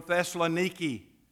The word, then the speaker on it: Thessaloniki: He said, "I am from Thessaloniki." That's the pronunciation of the ancient city of Thessalonica.